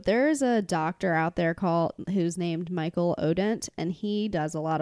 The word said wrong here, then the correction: Michel Odent: I think it's Michel Odent.